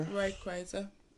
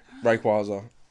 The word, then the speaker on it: Rayquaza: Rayquaza. – I love how you say it right, and you know I'm going to say it wrong. Rayquaza.